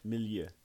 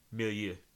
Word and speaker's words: milieu: And I think that speaks to it as a strong work in its Milo. – Mm-hmm. – I totally did not pronounce that word correctly, by the way. – Milieu. – Milieu.